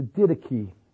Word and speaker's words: Didache: D-I-D-E-C-H-E, the Didache.